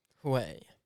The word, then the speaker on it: Hue: Hue. – That's how you pronounce it.